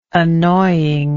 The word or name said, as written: annoyingness